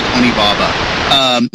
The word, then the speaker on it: Onibaba: As far as I know, it's called Onibaba.